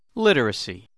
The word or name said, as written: literacy